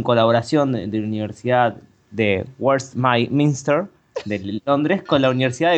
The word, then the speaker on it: Westminster: No te es de ahí, pero derecha, mejor pronunciado. – Claro, Westminster.